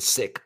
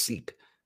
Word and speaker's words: Sikh: And none of them ever got mad when I said Sikh, because that's how I thought it used to be pronounced. And they would just say, no, it's pronounced Sikh.